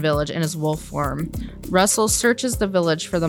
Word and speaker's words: Rusl: We got Russell. – All of these names, and that's the one that always gets me. – I know. Well, the funny thing is, it's Russell, but it's spelled R-U-S-L.